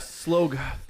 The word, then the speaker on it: Slough: Yeah, it's Slough, right? – Yeah. Do you remember them pronouncing it? – Okay. Slough.